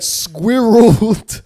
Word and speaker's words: squirrel: Some suggest that squirreled could be included, but squirrel is intended to be pronounced as two syllables.